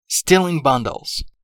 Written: stealing bundles

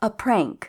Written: a prank

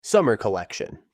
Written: summer collection

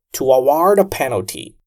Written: To award a penalty

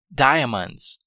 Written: diamonds

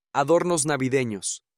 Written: adornos navideños

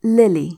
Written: lily

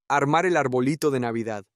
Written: armar el arbolito de Navidad

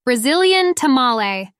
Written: Brazilian tamale

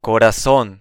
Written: corazon

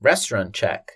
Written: restaurant check

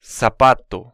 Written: zapato

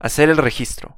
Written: hacer el registro

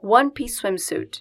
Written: one piece swimsuit

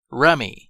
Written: rummy

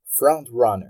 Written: front-runner

